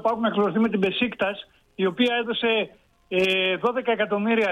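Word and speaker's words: Beşiktaş: Προφέρεται Μπεσικτάς, δεν είναι Μπεσικτάς, κάνουμε λάθος στη Θεσσαλονίκη, δεν το κάνουν όλοι. Είναι η λέξη προφέρεται Μπεσικτάς, είναι η περιοχή στην Κωνσταντινούπολη. Και μου αρέσει,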